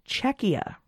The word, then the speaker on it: Czechia: According to a patron who is in the Czech Republic, Czech Republic is still a valid name, but the pronunciation is Czechia.